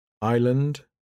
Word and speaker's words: island: The S isn't usually pronounced before an L. Isle, island, aisle.